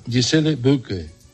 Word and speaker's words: Gisele Bündchen: Pronúncialo, es que trata de pronunciarlo. – Espérate. Sí, David Beckham, Carlos, empiezo. Matt Damon, Jessica Alba, Gisele Bündchen. – Eso es.